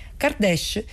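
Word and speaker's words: Kardeş: Kardesh, che si scrive Kardes con la K iniziale, ha un doppio significato.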